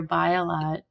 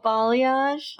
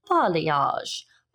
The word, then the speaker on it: balayage: I like what you're doing with your bialat. Well, how do you say it? – Balayage. I think. I always say it wrong, and I always want to ask my hairdresser, hey, can we do that? – Oh my God.